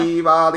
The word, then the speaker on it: Oliva: 那第一位是 Oliva Lin，好，他是说节目永远经营哦，哦，谢谢他哈，他希望我们的节目能够永远经营。那老师有没有什么话对他说？ – 我只是刚才听不懂你念他的名字是怎么念的。 – Oliva Lin，O L I V A。 – 哦。 Oliva Lee，对不对？好，好，感谢 Oliva 哈，那我们会很努力继续做下去哦。